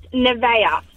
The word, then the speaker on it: Nevaeh: The heaven thing, the heaven spelled backwards, it's pronounced Nevaeh.